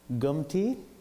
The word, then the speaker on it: Gumti: And I was born in a village called Gumti. So it's Gum and T. You spell it G-U-M-T-I.